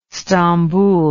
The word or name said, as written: stambul